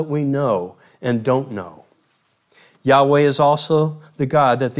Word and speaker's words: Yahweh: Yahweh is hypothesized to be the pronunciation of YHWH.